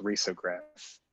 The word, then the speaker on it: risograph: It's, I guess it's technically pronounced risograph for the nerds, but.